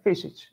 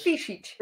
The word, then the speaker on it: Fisic: Uh, Fisic. It's like a little fish. Fisic. Fish which eats.